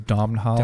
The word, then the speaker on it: Domhnall: But then the next shot is a eagle eye view of Dominic Gleeson. I don't know if I can say his name. – I think it's Domhnall. – Domhnall. – I think there's an N in there or something.